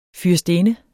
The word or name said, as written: fyrstinde